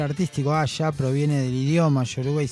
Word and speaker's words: Asa: Una es Asa, Asha le dicen, Asha, se escribe Asa,